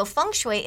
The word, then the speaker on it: feng shui: It's spelled F-E-N-G-S-H-U-I, mm-hmm. but we say feng shui.